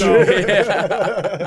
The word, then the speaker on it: Django: Django. Django with the silent D.